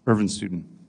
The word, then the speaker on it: Irvin Studin: Irvin Studin. – How do you spell that? – I R V I N S T U D I N.